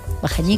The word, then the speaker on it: Bajañí: También a veces se escribe y se pronuncia Bajandí, pero bueno, creo que aunque se escribía Bajandí, también se seguía pronunciando Bajañí.